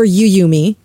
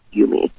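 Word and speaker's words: Yuumi: Is it Yumi or Yuyumi? – I've heard so many things. I've heard people call it Yummy. I believe the correct pronunciation is Yumi. – It is Yumi.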